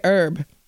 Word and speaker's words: herb: How do you say it? Do you say Herb? – No. Well, way? Americans say Herb.